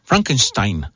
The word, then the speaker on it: Frankenstein: o como diría la estimadísima Clausen, que ella sí lo pronuncia bien, Frankenstein.